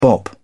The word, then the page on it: bop: British English